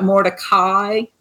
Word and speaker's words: Mordecai: It's spelled like Mordecai, but it's Mordecai.